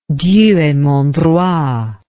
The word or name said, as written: Dieu et mon droit